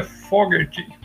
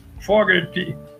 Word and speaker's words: Fogerty: E depois o John Fogerty, que a gente, no Brasil aqui, a gente sempre pronunciou errado o nome dele, né? Todo mundo aqui, né? Na verdade é Fogerty, com aquele R arrastado deles lá, né? – É verdade. – Fogerty.